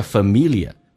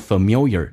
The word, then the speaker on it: familiar: 那么英式发音叫 familiar，美式发音有可能说成 familiar，那个 L 提前发，但一部分美国人说 familiar 都对， familiar 你要能听懂， Be familiar with 对什么很熟悉， content 指的是它的内容，直到你对它的内容比较熟悉了。